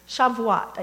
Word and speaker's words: Shavuot: I looked up the pronunciation. I think I got that. Shavuot, I think you say.